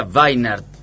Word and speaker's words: Weinert: Weinert, W como todo alemán, ¿no? – Por supuesto. – Nació en Colonia, Alemania, en el 64. – Se pronunciaría Weinert. – Weinert, así, ¿no?